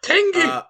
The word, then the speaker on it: TANGK: From the British punk band Idols, we have their new album T-A-N-G-K. Tank? I'm not sure how it's pronounced. – Tank!